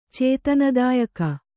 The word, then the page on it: ಚೇತನದಾಯಕ: kannada